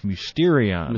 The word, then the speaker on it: mysterion: how do you pronounce it? It's mysterion, right? – Mysterion.